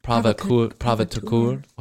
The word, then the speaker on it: provocateur: Provocateur. How do you say it? – Provocateur. – Provocateur.